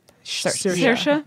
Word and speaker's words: Saoirse: Is that how you say her name? – Saoirse. Yeah.